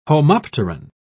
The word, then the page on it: homopteran: British English